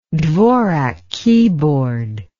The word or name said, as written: dvorak keyboard